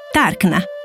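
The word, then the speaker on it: Tarchna: Dall'etrusco derivano anche molti nomi di città italiane come Tarquinia, che allora suonava Tarkna, e quello della stessa Cerveteri, che si pronunciava Caire, Caisra, per citare solo alcuni esempi.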